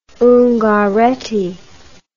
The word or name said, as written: ungaretti